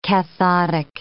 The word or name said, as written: cathodic